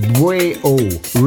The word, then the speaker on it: BWAO: BWAO, which is pronounced Bwayo.